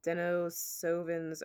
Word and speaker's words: Denisovans: It's spelled D-E-N-I-S. O-V-A-N-S. – All right, spell that for me one more time. – D-E-N-I-S. Penis, but with a D. – Okay, got it. – O-V-A-N-S. So Denisovans?